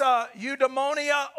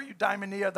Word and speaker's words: eudaimonia: It's eudaimonia or eudaimonia. I've heard it pronounced both ways. I prefer eudaimonia. That's the one I've heard most commonly.